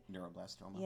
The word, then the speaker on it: neuroblastoma: To am I saying it right? – Neuroblastoma. – Yeah. Yes. Yeah. You, you, you are saying, saying, it that. right.